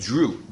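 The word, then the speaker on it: Dreux: It's like no. It's like Drew. It's like D-R-U-E. Drew.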